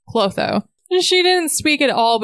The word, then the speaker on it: Clotho: Following this conversation, they arrived at the library where Rhys introduced Feyre to a priestess named, how do you pronounce it? – Clotho. – Clotho? Okay. Yeah, just how you would think it is.